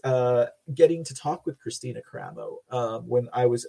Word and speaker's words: Kristina: They even pronounced her name here wrong, Kristen Karamo. They said, but it is Kristina Karamo, not Kristen.